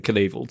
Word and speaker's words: Knievel: It's weird that the K is pronounced in Knievel too. – Yeah. – You don't say anyway, knife.